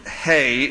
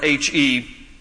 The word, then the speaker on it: He: Now, it's the Hebrew letter is just spelled H-E, So in English, we would look at that and say it's he, but it's pronounced he in Hebrew.